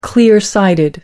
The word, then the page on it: clear-sighted: American English